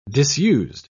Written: disused

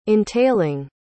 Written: entailing

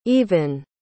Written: aoibhín

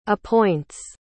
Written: appoints

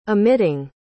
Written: emitting

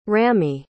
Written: ramy

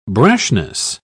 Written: brashness

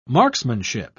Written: marksmanship

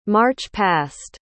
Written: march-past

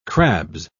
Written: crabs

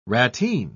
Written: ratteen

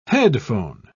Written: headphone

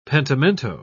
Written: pentimento

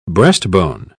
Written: breastbone

